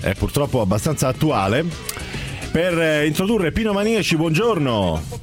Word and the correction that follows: Maniaci: È una questione di accento. – Maniaci, giusto, scusa.